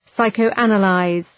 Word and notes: psychoanalyze: {,saıkəʋ'ænə,laız}

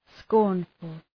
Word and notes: scornful: ‘skɔ:rnfəl

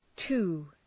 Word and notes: too: tu:

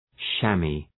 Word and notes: chamois: ‘ʃæmı, ʃæm’wɑ:

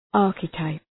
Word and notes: archetype: ‘ɑ:rkı,taıp

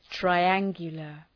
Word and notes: triangular: traı’æŋgjələr

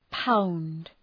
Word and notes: pound: paʋnd

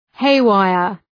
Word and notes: haywire: ‘heı,waıər

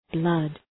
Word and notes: blood: blʌd

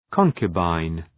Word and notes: concubine: ‘kɒŋkjə,baın

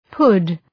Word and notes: pud: {pʌd}

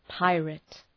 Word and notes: pirate: ‘paıræt

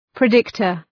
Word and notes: predictor: prı’dıktər

